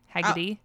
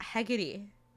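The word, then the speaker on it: Heckedy: It's H-E-C-K-E-D-Y, Heggity. – Oh, Heggity.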